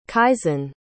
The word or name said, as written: kaisen